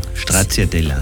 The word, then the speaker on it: Stracciatella: Stracciatella, weil ich die kleinen Schokostückchen in dem Eis sehr gern mag. – Noch so schön ausgesprochen. Stracciatella. – Genau. – Ich sage immer Stracciatella.